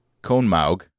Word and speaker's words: Conemaugh: Located in a valley among the Laurel Mountains in Pennsylvania, the Conemaugh, as I would like to pronounce it, Conemaugh.